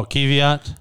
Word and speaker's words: Kvyat: Oh, Kvyat. – Okay. Kvyat.